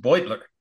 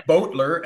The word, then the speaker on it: Beutler: I would love to ask him, especially because the first key insight is his name is pronounced Beutler. not Beutler, as in Beauregard. – Or Beutler, as in Butte, Montana. – Or as in Butler, or any of the other combinations. No, but Beutler.